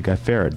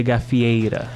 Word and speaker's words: Gafieira: However, at the beginning, we are talking about Samba de Gafieira. Did I get that pronunciation right? – Almost. It was Samba de Gafieira.